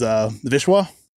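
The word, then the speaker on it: Wisła: And that's a fun one because that's named Vistula, which is a name of a river in Poland, and that's actually the German pronunciation. But what I wanted to originally call the beer was Vishwa,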